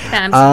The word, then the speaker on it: VAMS: Talking more about like VAMS, I'm hoping I'm saying that right. – Yeah, VAMS. – VAMS. Oh wow. – like VAMS, but VAMS. Yeah, it's like shoe company.